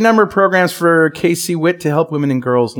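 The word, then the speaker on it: KCWIT: Kickwit. Is that how you say it? Kickwit? – K-C-Wit.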